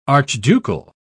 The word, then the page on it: archducal: British English